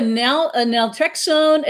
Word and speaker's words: naltrexone: So you're a naltrexone administration trainer.